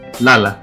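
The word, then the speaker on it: Lalah: Πάλι κοντά σας, ακούσαμε Robert Glasper Experiment μαζί με Lala Hathaway και τον Lala, έτσι προφέρω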